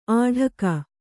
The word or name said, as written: ಆಢಕ